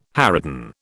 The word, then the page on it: harridan: British English